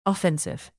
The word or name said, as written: offensive